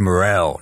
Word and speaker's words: Morrell: Makes no sense phonetically. It's one of those annoying things where my dad's like, it's Morrell, not Moral.